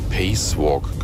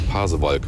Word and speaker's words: Pasewalk: Ich hatte früher mal einen Kollegen, der immer gesagt hat, ich bin durch Pacewalk gefahren. Lange, lange habe ich überlegt, was er damit meinte, bis wir mal drauf kamen. Der Pasewalk.